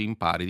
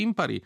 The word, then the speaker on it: impari: Si sposta l'accento, non dici impari, dici impari.